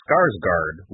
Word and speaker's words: Skarsgård: They're going to split it up into two films, but in either case, they went with Bill Skarsgård, which is something that is hard to pronounce because there's an umlaut involved. – Skarsgård? – Scorgan Morgan. – Yep. Just like that.